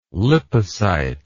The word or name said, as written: lipocyte